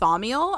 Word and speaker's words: Thaumiel: So level 5, which is pronounced Thaumiel, I think.